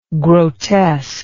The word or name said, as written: grotesk